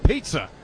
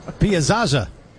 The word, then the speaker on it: Piazza: How many times they mispronounced that name? – Mike Pizza. – Yeah. Piazza.